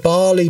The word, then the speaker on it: Bali: Yeah, was it Marley? It was in Marley where that huge massive bomb went off. – The Bali bomber. – Barley. Yeah, that's right. I have the pronunciation wrong.